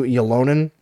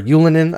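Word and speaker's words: Ylönen: Along with Drouin, big shout-out to Yolonen, or Yulinen, I guess is the proper way to pronounce it.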